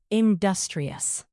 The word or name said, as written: industrious